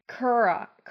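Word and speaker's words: Kuruk: Kurok. – Kurok. – Kurok. Sorry. – Kurok. Yeah, you've been saying Kurok. – I've just been saying it bad. Sorry. Kurok. It's K-U-R-U-K.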